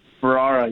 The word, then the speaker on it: Ferrara: Jake, am I mispronouncing your name? Is it like, can it be said both ways, like tomato, tomato? – You got it right. Ferrara, you got it.